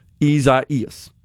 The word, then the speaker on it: Isaias: So an extra syllable in there, it would appear, from the way it's typically pronounced, spelled I-S-A-I-A-S. So, seems like we kind of insert an extra E in there between the I and the A.